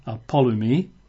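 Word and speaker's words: apollumi: It's apolumi. I'll spell that. It's A-P-O-L-L-U-M-I. A-P-O-L-L-U-M-I. Apolumi. And that's just the same word as before, but this is a verb, to destroy.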